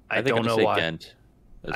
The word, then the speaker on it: Ghent: So I had to ask, I was like, how do you pronounce this? And they're like, that's Ghent. I was like, oh, okay, that's how you say it.